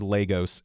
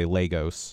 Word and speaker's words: Lagos: This, I believe, the largest city in Nigeria, L-A-G-O-S. I want to say Lagos, but I think I've heard Lagos, and so I'm actually going to go with that long A, Lagos. – The long A is right. – You're correct. – But no, it's not really ghost, though. It's more Gus, isn't it? – Lagos. – Isn't it Lagos? – Lagos, yeah.